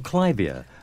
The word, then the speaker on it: clivia: Oh, clivia. Split. I'm divorced? You never know. Hello there. Welcome to the show. – Good morning. Good morning. – Good morning. – And it's pronounced clivia, and clivia.